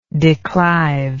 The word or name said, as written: declive